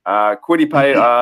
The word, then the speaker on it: Kwity Paye: And now I've done a little bit of the AFC West already, so this is going to come into, you know, Kwity Paye.